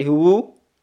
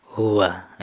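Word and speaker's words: Huwa (هو): Huwa ini akhirnya selalu fathah, ya, tidak bisa kita katakan menjadi huwu, tidak bisa menjadi apa? Huwi. Ya, jadi akhirnya fathah saja, jadi jangan dirubah-rubah. Walaupun dia gundul, ya kan, tidak ada rokatnya, kita bisa baca. Apa bacanya? Huwa. Ya, jangan sekali-kali baca huwu atau huwi.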